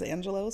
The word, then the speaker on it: Angelou: So Maya Angelou was actually born Marguerite Annie Johnson, and I always thought her last name was, because it's spelled L-O-U, I always thought it was pronounced Angelou until I listened to one of her books that she reads, and she pronounces it herself as Angelou.